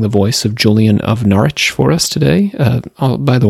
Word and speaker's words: Norwich: Otherwise, I would be totally out to sea when it comes to pronouncing Norwich, which looks like Norwich, for those of you who look this up online.